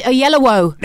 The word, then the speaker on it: Oyelowo: How do you say his name? Yololo. – David Oyelowo.